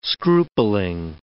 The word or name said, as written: scrupling